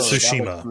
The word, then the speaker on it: Tsushima: Ghost of Tsushima.